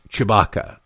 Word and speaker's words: Tshibaka: Her name is spelled, it's Kelly, T-S-H-I-B-A-K-A. But it's like it's pronounced Chewbacca.